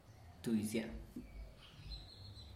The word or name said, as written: tuizia'a